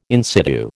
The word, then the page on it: in situ: American English